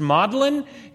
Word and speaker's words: Magdalene: And so one of the things that has happened is that in English countries, especially, Magdalene is often pronounced Maudlin. It's where we get the word Maudlin to mean sad or sorrowful.